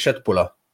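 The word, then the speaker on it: Köttbullar: Aussprache ist Schöttbuller.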